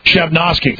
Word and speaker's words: Chelyabinsk: Chelyabinsk.